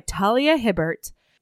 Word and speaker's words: Talia Hibbert: And get ready, because we are reading Mating the Huntress by Talia Hibbert. We messaged her, and that is, in fact, how you pronounce her name.